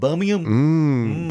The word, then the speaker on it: Birmingham: And then Birmingham. That's how you say it. Birmingham.